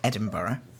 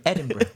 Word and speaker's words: Edinburgh: Well, that's how Americans say it, but I think that most British people say Edinburgh. – Edinburgh.